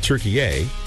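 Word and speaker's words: Türkiye: which is now pronounced Turkey A.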